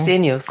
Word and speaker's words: Xenius: Von 2011 bis 2013 übernimmt sie auf Arte das Wissensmagazin Genius. Ist das so korrekt ausgesprochen? – Xenius. Xenius, mhm.